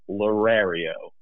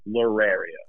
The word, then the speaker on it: Lerario: Okay, so it's Lerario. – Lerario. – Kind of like Mario at the end, Lerario.